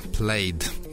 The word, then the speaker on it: Plaid: Я думаю, идеальным было бы название для группы Камин, а вот эта группа называется просто Plaid, Plaid, если произносить это правильно по-английски. Как раз английский же электронный ансамбль.